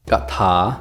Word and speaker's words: กถา: สวัสดีครับวันนี้พบกับรายการคำวัดสำหรับวันนี้เสนอคำว่ากะถาคำว่ากะถาสะกดด้วยกอไก่ถอถุงสละอากถาคำว่ากถา